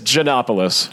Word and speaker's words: Giannopoulos: Did I pronounce that correctly? – No. – All right. Okay. Off to a good start. – Let me stop you there. Let's get proper pronunciations down and then just record us saying. – I kind of like the fact that I botched it, and he was like, "No." – Okay. No, it's Giannopoulos.